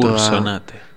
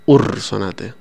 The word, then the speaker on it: Ursonate: insistió en la importancia de pronunciar esa R. Y hoy he vuelto Ursonate. – Hoy he vuelto a menospreciarlo. Ursonate.